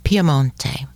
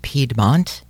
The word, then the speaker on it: Piemonte: Quickly, to the west of Milano is Piemonte, also as they pronounce it in Italian. The Americans in English say Piedmont, which is almost as bad as Lombardy.